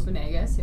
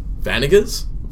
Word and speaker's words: Venegas: I called him Vanegas for like two episodes before Charles actually weighed in and was like, so you've been saying my name wrong for the whole time we've been on air.